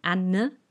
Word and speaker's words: Anne: Anne, A-N-N-E, also Doppel-N und ein E noch dran.